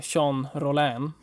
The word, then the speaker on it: Jean Rollin: Då har vi Jean Rollin, om jag uttalar rätt.